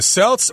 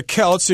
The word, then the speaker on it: Celts: The Celts or the Celts, you can pronounce it both ways.